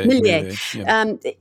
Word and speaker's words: Milei: Javier Milei.